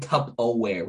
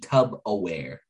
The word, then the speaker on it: Tupperware: You're saying Tupperware with a B. Tub-aware. – I'm saying Tupperware. So yeah.